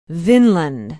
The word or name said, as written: vinland